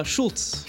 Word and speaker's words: Schultz: Andre, how do you pronounce your last name? – Schultz. – Schultz, okay. Really? – Yeah. – Oh, okay. – It's German, but it's spelled Polish. – It's spelled Polish, okay.